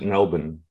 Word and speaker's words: Melbourne: Melburnians pronounce it Melbourne.